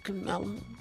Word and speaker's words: Kamala: You know, it's just cool the way to say, what's up, Kamala? – That's how you say it.